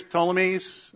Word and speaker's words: Ptolemy: So here's Ptolemy's. P is silent, like pterodactyl.